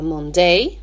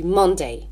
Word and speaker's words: Monday: Monday, on ne dit pas Monday, on dit Monday.